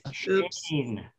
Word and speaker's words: Schoen: Got his name wrong. Shane. – It's early. – Let's try that again. I'm going to cut this. – I can't do it. How do I say it? – Shane, like S-H-A-N-E.